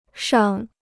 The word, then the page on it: 尚: shàng